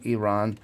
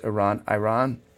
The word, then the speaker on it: Iran: The individual that asked him the question was from Iran and was quite forceful about the fact that people, and I've been guilty of this, who pronounce Iran, is not pronouncing the name of the country right. It's Iran.